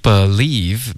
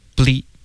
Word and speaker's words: believe: OK Believe 别发成 ble OK 这是一个音节，我们要 believe 两个音节。